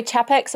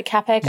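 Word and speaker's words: Čapek: Some people say Chapek, some people say Capek.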